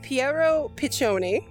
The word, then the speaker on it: Piero Piccioni: and please correct me if I'm saying his name wrong, Piero Piccioni. Did I say that right? – Yeah. – Yes. He's that score.